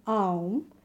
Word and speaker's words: Aum: So the way we pronounce Aum or Om in your language is So as you heard me, it's A, U, and M. So you have to pronounce each of these sounds equally in the best way you can.